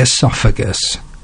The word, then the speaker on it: oesophagus: How about something that doesn't sound like it begins with the letter O when you say it, but I'm going to say it. The esophagus. Yes. I bet you didn't expect me to talk about this today. It's spelled O-E-S-O-P-H-A-G-U-S, the esophagus,